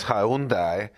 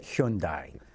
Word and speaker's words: Hyundai: In Korea, and I learned this from Koreans 25 years ago, they pronounce it Hyundai. That's how they say it.